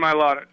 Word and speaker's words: Mailata: Mailata? What is it? – Jordan Mailata. Jordan Mailata.